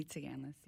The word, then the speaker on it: Tsiganlis: Amy Tsiganlis, yeah. Silent S in the middle.